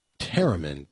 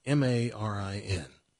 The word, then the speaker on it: tamarin: Taramin. Tamarin. While Peon looks it up, they have cotton top taramin poop. It's pretzel pearls. – How do you spell it again? – Tamarin. – R-I-N.